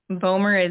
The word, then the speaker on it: Bohmer: So Julie is J-U-L-I. Bohmer is B-O-H-M-E-R.